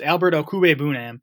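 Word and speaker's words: Okwuegbunam: I was looking up the pronunciation on his name. It's not as hard as you might think. It's O. Kuwe Bunam. So it's Albert Okuwe Bunam.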